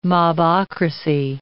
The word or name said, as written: mobocracy